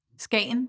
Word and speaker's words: Skagen: And there are festivals, even festivals in Denmark, like in, I don't know if I pronounced that right, Skagen? – Skagen, yes, Skagen. That's correct.